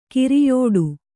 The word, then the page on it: ಗುರಿಯಿಡು: Kannada